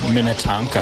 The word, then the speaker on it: Minnetonka: John, it's pronounced Minnetonka.